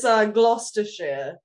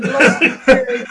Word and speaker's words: Gloucestershire: Yeah, it's Gloucestershire. – Is that right? – Yeah. So Gloucestershire.